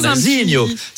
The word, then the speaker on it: Nazinho: C'est vrai que ça se prononce avec l'accent chantant, Nazinho.